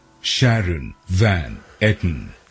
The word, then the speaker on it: Sharon Van Etten: And I actually just looked up her, how to pronounce her name, because I'm so worried I was going to get it wrong. And if you Google it, how to pronounce it, this is what comes up. – Sharon Van Etten. – So I believe I'm saying it correctly.